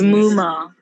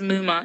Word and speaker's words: Muma: It's M-U-M-A. – No? – People call me Muma, Mom. Like, are you taking the mic? But you can say Jean-Paul Gaultier. You even like, well, go and learn how to say that whole thing, but you can't say Muma.